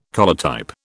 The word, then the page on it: collotype: British English